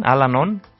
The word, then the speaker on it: Al-Anon: Vamos a revisar también algunos de los eventos que vamos a tener con la Oficina de Vocaciones, pero nos acompaña de Al-Anon, si lo pronuncié bien, Amparo. Sí. Bienvenida, Amparo, buen día, ¿cómo estás? – Sí, buenos días, muchas gracias por invitarme. – Con mucho gusto. ¿Sí lo pronuncié bien, Al-Anon? – Sí.